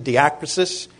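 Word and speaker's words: diakrisis (διάκρισις): It's diakrisis. Notice the little mark over the alpha. That means you pronounce that syllable, you stress it, diakrisis.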